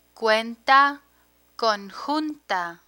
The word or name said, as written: cuenta conjunta